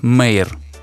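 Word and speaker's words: Mare: Ее зовут Мэйр. – А, или, окей, или просто... ее, наверное, на русский перевели как мэр просто, наверное. ее имя пишется как М-А-Р-И. Мэр.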